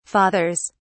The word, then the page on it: fathers: British English